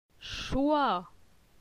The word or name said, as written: Choi